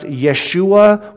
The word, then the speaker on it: Yeshua: So Joshua was kind of, it can be pronounced Yeshua, which some of you maybe have heard that term before.